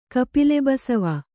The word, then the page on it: ಕಪಿಲೆಬಸವ: Kannada